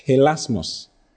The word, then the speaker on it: hilasmos: The first one is hilasmos. Three words for propitiation. The first one is hilasmos. Hilasmos is spelled H-I-L-A-A-S-M-O-S. H-I-L-A-S-M-O-S. Hilasmos. Hilasmos.